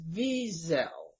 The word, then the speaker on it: Wiesel: I don't like to emphasize that last syllable like it's a kind of a French name or something. The proper way, as far as I understand, is Wiesel. Wiesel.